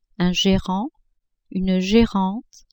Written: gérant, gérante

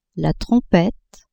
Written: trompette

